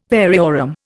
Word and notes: variorum: Indian

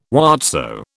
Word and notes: whatso: British English

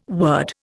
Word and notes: word: Indian

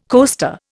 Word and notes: coaster: Indian

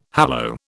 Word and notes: hallow: British English

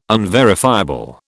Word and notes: unverifiable: British English

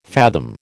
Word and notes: fathom: American English

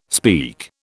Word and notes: speak: British English

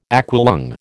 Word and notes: aqualung: American English

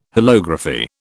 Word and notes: holography: British English

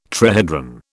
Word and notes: trihedron: British English

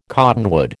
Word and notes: cottonwood: American English

